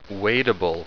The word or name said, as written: wadeable